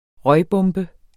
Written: røgbombe

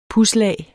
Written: pudslag

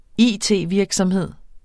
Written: it-virksomhed

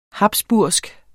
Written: habsburgsk

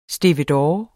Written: stevedore

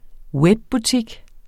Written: webbutik

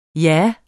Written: jah

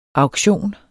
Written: auktion

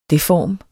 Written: deform

